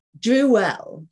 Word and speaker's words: Drewell: Drewell. Yes. Or you can say Drewell like the spittle, but you know, Drewell sounds a bit more fabulous.